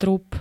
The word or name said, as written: trup